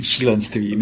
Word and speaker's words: Šílenství: Oh ja, Šílenství. – Šílenství. – Šílenství mit einem langen I hinten.